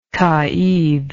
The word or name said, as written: caid